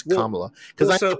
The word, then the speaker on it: Kamala: No, I think Kamala.